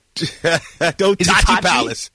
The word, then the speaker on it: Tachi Palace: How is this pronounced? Is it Tachi Palace Casino Resort in Lemoore? – Tachi Palace. Yeah.